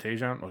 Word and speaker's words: Taijuan: Is that Tajon? Okay, Taijuan Walker. Yeah. So, however, there's a J in there. How do you not pronounce the J? – It's silent.